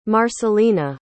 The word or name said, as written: marcelina